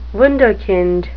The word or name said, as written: wunderkind